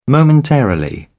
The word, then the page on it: momentarily: British English